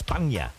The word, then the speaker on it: Spagna: This song was quite interesting because it's spelt different than how it's pronounced. Now, it's spelt Spagna. And many people think that's what it is, but it wasn't. It's Spagna. That's how you pronounce it.